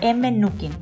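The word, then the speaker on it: Mnookin: Es con M primero, M Nukin, ¿ok?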